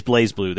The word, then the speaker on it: BlazBlue: It's very, like, the opening of Blue Blaze, or BlazBlue, I keep saying it wrong, and I keep wanting to say BlazBlue because they leave the E off of Blaze, but it is BlazBlue.